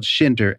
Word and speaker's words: Shinder: And my last name is spelled Schinder, S-H-I-N-D-E-R.